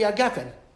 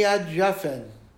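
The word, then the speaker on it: hagafen: So, and similarly, gimel with a dot in it is ga, and gimel without a dot is ja. So for example, b'rei pri agafen. The bracha on wine, if you ever heard a Yemenite say, B'rei pri adjafen. That's what they say, B'rei pri adjafen. It's a soft G, right?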